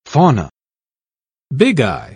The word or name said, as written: fauna